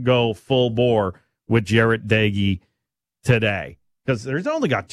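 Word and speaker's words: Doege: Even though Micah Johnson found out it's pronounced Daigie, not Doge, earlier this week with Britton Gray.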